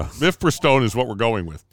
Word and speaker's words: Mifepristone: ruled that Mifepristone, which had been approved by the FDA for decades, and... – Nailed the pronunciation, by the way.